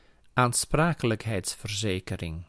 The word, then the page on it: aansprakelijkheidsverzekering: /aːn.ˈspraː.kə.lək.ˌɦɛi̯ts.fər.ˈzeː.kə.ˌrɪŋ/